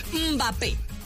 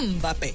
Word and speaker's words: Mbappé: Otro, Mbappé. No, Mbappé.